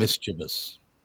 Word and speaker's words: mischievous: Mischievous. – Oh, because they say mischievous. – Mischievous, yeah. It's mischievous, not mischievous. Look at how it's spelled.